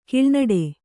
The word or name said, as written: ಕೀಳುನುಡಿ